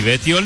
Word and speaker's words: Betiol: Y Betiol, mi apellido, se escribe con B larga y dos T. Todos juntos, Pablo Kika Betiol.